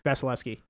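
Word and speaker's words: Vasilevskiy: I can't pronounce. It's Vasilevsky? – Vasilevsky. Yeah.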